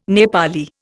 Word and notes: Nepali: Indian

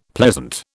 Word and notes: pleasant: British English

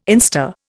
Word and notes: instar: Indian

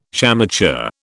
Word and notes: shamateur: British English

